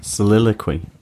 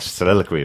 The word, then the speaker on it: Soliloquy: I can never pronounce that first time. – Soliloquy. – That's it. Soliloquy of Chaos.